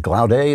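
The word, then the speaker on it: Glaude: Here is, I think his name is Gloud. He pronounces it Gloudé.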